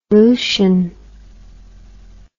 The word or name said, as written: Dakins solution